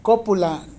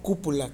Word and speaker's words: cópula: acento en la O, cópula, no cúpula, que la cúpula es otra cosa, es la de las iglesias.